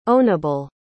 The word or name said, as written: ownable